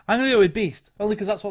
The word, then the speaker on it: B2ST: The next one, they're called Beast, and they're spelled B2ST.